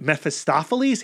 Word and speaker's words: Mephistopheles: Obviously, he's loosely inspired by the character of Mephistopheles. Hey, I said that right this time. – You did, nailed it.